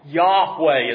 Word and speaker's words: Yahweh: Yahweh is how it is typically pronounced by those who pronounce it, but most people will not pronounce it because of a Jewish reverence for this name.